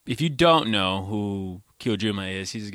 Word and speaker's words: Kojima: Next is Hideo Kojima. Did I say that right? – Kojima. – You added an R.